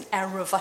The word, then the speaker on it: eruv: The creation of an, I think it's an eruv, I think that's how you pronounce it, E-R-U-V.